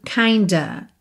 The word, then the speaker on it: kind of: Kind of. Kind of. Muitas vezes é pronunciado kinda.